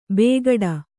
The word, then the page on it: ಬೇಗಡ: bēgaḍa